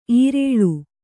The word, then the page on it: ಈರೇಳು: īrēḷu